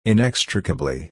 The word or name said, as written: inextricably